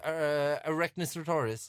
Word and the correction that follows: Tyrannosaurus Rex: Tyrannosaurus Rex.